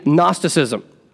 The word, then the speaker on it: Gnosticism: Starts with a G, silent G, Gnosticism.